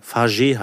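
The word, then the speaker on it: Fage: Fagé heißt der. Fagé, griechisch. Man merkt es sofort. – Mhm. – Es steht drauf, so wird es ausgesprochen.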